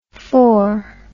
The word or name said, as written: phore